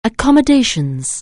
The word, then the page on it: accommodations: anglais